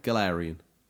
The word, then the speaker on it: Galarian: Galarian. – We'll go with Galarian culture and even nab some photo opportunities with some Galar-specific Pokemon.